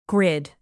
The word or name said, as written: grid